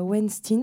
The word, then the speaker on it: Weinstein: L'idée, c'était de revenir quatre mois après euh, l'affaire euh, Weinstein, parce que c'est comme ça qu'on prononce son nom en vrai, euh, sur voilà, qu'est-ce qui a changé.